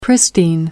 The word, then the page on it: pristine: American English